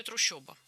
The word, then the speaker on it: трущоба: Ну, нам, наверное, не так-то принципиально, как то, что там действительно пишется «о». Как и в слове «трущоба». Хотя слышится очень ёшно, по-моему. – Трущоба, очень ёшно.